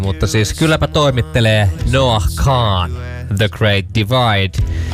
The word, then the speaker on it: Kahan: Hyvä, vomma ton mulle opetti, kun mäkin oon aina sanon Noah Kahan, ja sit vomma oli vaan I don't want to be this guy, but it's Kahan.